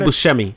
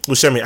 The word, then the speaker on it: Buscemi: Steve Buscemi. – Buscemi.